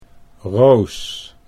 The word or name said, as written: Roos